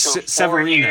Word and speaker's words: Severino: Uh, another MLB news, the Yankees and Luis Severino, if I said that correctly, Luis Severino.